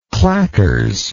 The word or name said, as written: clackers